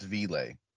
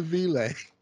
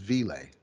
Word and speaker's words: V.I.L.E.: It's pronounced V-Lay. yeah, it's the Vile. What we know, what we call the vir- virtual infant learning environment I'm like, wait, that says vile. No, it's V-Lay. Sorry, sir.